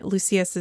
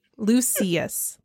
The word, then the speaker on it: Lucius: And Lucius is, I'm really self-conscious about the way I say his name now after we got corrected on the pronunciation. – Oh, right. – Lucius. – Lucius is literally telling Draco, I can't believe you let that mudblood girl outperform you on all your exams.